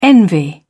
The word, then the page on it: envy: British English